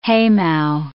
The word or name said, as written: haymow